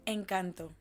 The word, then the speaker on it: Encanto: well not Sing but uh, how you pronounce that movie Encanto. – Encanto.